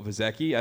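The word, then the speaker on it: Visacki: Michael Vizecki, I think I'm pronouncing his name correctly, yep.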